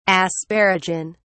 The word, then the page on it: asparagin: British English